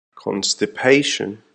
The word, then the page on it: constipation: American English